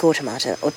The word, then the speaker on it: automata: The current era of algorithmic automata, how do you say this? – Automata. – Automata? – Like automaton. – Yeah, but so, like, yeah. Plural. – Yeah. – Automata. – Like it's the same.